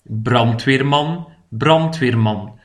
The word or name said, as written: de brandweerman